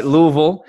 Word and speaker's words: Louisville: Louisville, yeah, I think I said it right. Louisville.